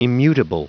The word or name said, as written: immutable